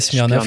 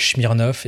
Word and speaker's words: Smirnoff: qui peut-être un peu comme Masters of Mal qui a fait genre euh, bon euh, il y en a qui n'arrêtent pas d'écorcher notre nom qui nous appelle Smirnoff,